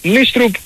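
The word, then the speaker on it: Nystrup: Οπότε τη στιγμή που ανακοινώθηκε επίσημα την προηγούμενη εβδομάδα ως Νίστρουπ. Έτσι μας τον είπανε, έτσι θα λέμε και προχωράμε.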